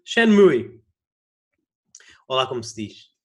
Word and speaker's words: Shenmue: e a pronúncia correta é Shenmue.